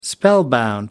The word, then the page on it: spellbound: American English